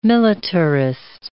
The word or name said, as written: militarist